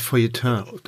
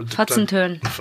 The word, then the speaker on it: Feuilleton: das Wort Feuilleton auch nicht aussprechen. Ich habe es auch falsch ausgesprochen. – So, aber Fotzentön.